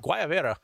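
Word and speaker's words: guayabera: Chris Heath, I think the G is silent in Guayavera. Gil? No, it is in Gil. It is in Gil or Gilberto or whatever you want to say with that. But in Guayavera, all my Cuban friends pronounce the G as a hard G.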